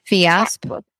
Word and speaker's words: Fiasp: So we tried to get the, how do you say it? The Fiasp? The Fiasp? – Yeah. But they told us that my endocrinologist said that it's a non-preferred drug with our insurance plan.